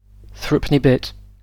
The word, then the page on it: threepenny bit: ˈθɹʌpəni bɪt; British English